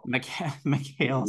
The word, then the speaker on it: Mikhail Salahi: The casting for DC was so bad, though, except for, I also call her Michelle, but I know that's not actually how you pronounce it. Michelle Salahi. – Mikhail. – Mikhail Salahi.